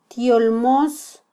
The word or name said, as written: tyolmos